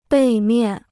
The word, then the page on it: 背面: bèi miàn